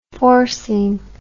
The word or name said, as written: forcing